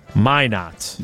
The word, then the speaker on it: Minot: The correct pronunciation of Minot, North Dakota is Minot.